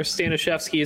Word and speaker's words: Staniszewski: Also, for those unaware, Staniszewski is actually the Polish correct way to say my last name.